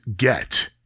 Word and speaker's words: git: It is spelled G-I-T, but Northerners tend to pronounce it get.